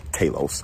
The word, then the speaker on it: Talos: Sam Jackson taught me the proper way to pronounce that. Talos.